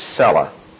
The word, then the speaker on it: Selah: which is cella. That's transliterated, by the way, because that's the Hebrew, way the Hebrew word is pronounced.